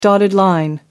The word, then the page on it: dotted line: American English